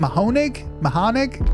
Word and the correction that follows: Mahoning: I mean, I'm Southern, so I'll probably fuck it up too, but Mahoning. – How do you say it? Mahoning. – Yeah. – Mahoning, right? – Mahaning.